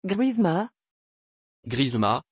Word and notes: grishma: French

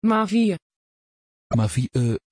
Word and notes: Mavie: Dutch

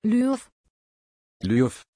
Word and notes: Lyubov: German

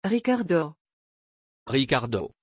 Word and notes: Riccardo: French

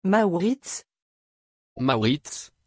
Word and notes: Mauritz: French

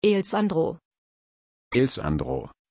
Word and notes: Alesandro: German

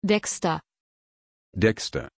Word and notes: dexter: German

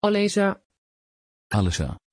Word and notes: Alessa: Dutch

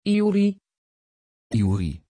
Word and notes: iouri: Dutch

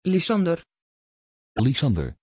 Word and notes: Lysander: Dutch

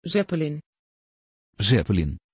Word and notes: Zeppelin: Dutch

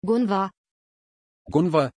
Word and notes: gunnvarr: German

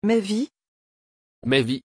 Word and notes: Maivi: French

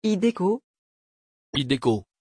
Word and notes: hideko: French

